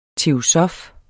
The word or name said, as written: teosof